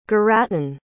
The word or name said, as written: garatun